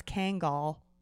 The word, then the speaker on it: Kangol: He's called a Kangol hat, right? – Yeah. Kangol? – Sounds like a Kegel. – No, it's Kangol.